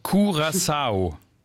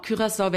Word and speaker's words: Curaçao: Also richtige Aussprache, Curaçao. – Wenn man es wie die Inselbewohner machen möchte, genau. Curaçao wäre die niederländische Variante, habe ich aber auch oft dort gehört, geht beides.